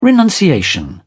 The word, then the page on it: renunciation: British English